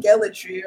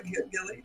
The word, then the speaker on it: Gellaitry: And um, Sam Gellaitry, or how do you say it? – Yeah, you and, said it. Uh, yeah. okay, I was like Gilly